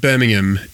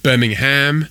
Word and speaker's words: Birmingham: And it's pronounced Birmingham if you live in England. It's not Birmingham. Certainly Aussies love to call it Birmingham, and they call Nottingham Nottingham. But it's Birmingham.